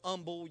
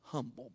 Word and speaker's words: humble: Oh, you're so humble, you can't even pronounce the H, right? It's humble, by the way. Humble. I can't do nothing.